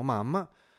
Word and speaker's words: MUM: M-U-M, ehm, però lo pronunciano Mam ehm, che è l'ultimo algoritmo basato su, appunto sull'artificial intelligence.